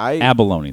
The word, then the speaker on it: abalone: I abalone. That's what I'm going to get next time. Never cooked. Have you ever cooked an abalone? Am I even saying it correctly? – You are saying it correctly.